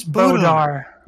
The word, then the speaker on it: Bodar: Hey, Mr. Boldor, or is that how you pronounce it? – His name's Bodar. – Boldar. – There's no L.